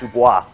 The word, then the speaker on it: Du Bois: Du Bois, and that's how he pronounced his name, not Dubois, Du Bois,